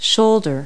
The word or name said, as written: shoulder